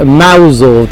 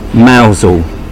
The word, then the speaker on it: Mousehole: But that's not how it's pronounced, is it? – No. We pronounce it Mousehole down here. – Mousehole.